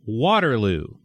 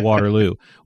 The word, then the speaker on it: Waterloo: They say Waterloo instead of Waterloo. Waterloo. I mean, technically they pronounce it right, but they emphasize Waterloo.